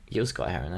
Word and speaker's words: Gil Scott-Heron: Is it Gil Scott Heron or Jill Scott Heron? How do you say it? – I say Gil. – Gil Scott Heron. That's how I say it too.